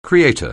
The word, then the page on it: creator: anglais